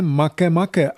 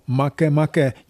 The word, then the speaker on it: Makemake: Jmenuje se Makemake a má 20. narozeniny. Ano, Makemake, nikoli make-make, jak by k anglické výslovnosti mohl napsaný název svádět.